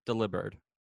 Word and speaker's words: Delibird: And it's meant to be pronounced like delivered. Delivered. – Delivered. – Delivered. – Your package has been delivered. – Deliberate. – There you go. – Starring Delibird.